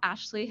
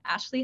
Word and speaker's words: Ashlie: Where you can find me, I'm like at Ashley Head everywhere, like Instagram, LinkedIn. I spell my name really weird. It's spelled A-S-H-L-I-E.